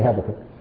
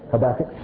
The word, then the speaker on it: Habakkuk: How do you say Habakkuk? – Habakkuk. – Say it. – Habakkuk. – That was all right.